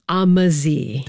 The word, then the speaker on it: Ammersee: So it's pronounced Ammersee. – That's right.